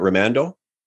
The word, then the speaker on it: Raimondo: Did I pronounce his last name at Raimondo? – Raimondo. Yeah. – Raymondo. Yeah. Okay, fantastic.